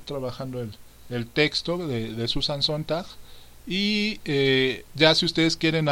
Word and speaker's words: Sontag: El texto se llama Ante el dolor de los demás, de Susan Sontag.